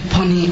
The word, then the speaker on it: Ponni: I'm Dr. Pani Arunkumar. The first name is spelled P-O-N-N-I. The last name is spelled A-R-U-N-K-U-M-A-R.